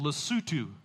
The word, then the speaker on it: Lesotho: There's a little landlocked nation called Lesotho, is how you say that.